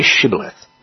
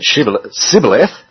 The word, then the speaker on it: shibboleth: If he replied no, they said, "All right then, say Shibboleth." If he said Sibboleth, because he couldn't pronounce the word correctly, they seized him and killed him at the fords of the Jordan.